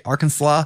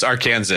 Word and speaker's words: Arkansas: Am I saying that right? Arkansas? – No, it's Arkansas. Arkansas. I just don't know how to pronounce anything.